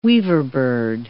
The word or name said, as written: weaverbird